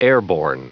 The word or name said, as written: airborne